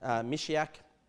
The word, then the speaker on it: Messiah: We see that he was anointed, the most holy, Meshach, and is the Messiah, Meshach, as some people pronounce it.